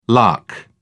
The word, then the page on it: lakh: British English